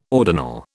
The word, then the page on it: ordinal: British English